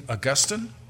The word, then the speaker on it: Augustine: Um, to understand who Monica is, we have to know who Augustine is, or I think really, uh, uh, probably better pronounce his name Augustine, but um, j- just to give you some appreciation, some comments made about him.